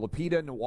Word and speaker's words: Lupita Nyong'o: Lupita Nyong'o.